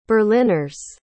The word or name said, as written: berliners